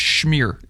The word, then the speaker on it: schmear: He gets the bagel, and give him some smear on that bagel, too. And then that's pronounced schmear.